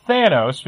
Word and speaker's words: Thanos: we would say Thanos. And this 4-year-old corrected me and said, no, it's Thanos, because apparently that's how they pronounced it on the cartoon that was on at the time. – Yes. – Imagine my surprise when, even though they've screwed it up in the first Guardians, that's apparently the official pronunciation.